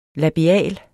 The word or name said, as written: labial